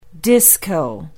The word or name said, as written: disco